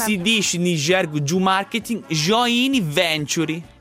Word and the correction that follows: joint venture: Joint venture. Pensa, io che correggo qualcuno in inglese per la pronuncia.